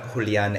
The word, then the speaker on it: Julian: If you want to get into it, my name is Julian Hernandez. People have a hard time early on saying Julian because that's the Spanish pronunciation.